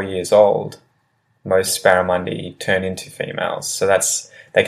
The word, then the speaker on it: barramundi: So the first one is barramundi, and this is a really large fish that lives in rivers. Barramundi, barramundi. And barramundi is spelled B-A-R-R-A-M-U-N-D-I, barramundi.